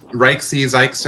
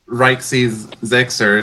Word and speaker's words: RXYZYXR: Uh, it's spelled R X Y Z Y X R, and it's pronounced like Rixy Zixer or Rixy Zixer or something like that.